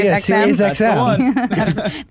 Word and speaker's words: SiriusXM: Yeah, SiriusXM.